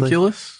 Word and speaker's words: homunculus: with a homologous, or am I pronouncing that correctly? – Homunculus? – Yes.